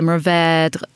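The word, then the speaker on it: Mourvèdre: If you're going to say it, I guess, as a Texan, but and the French would say Mourvedre with a little bit of a accent on the end.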